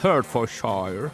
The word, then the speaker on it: Hertfordshire: No, è mistero in Inghilterra perché da alcuni giorni nel Hertfordshire.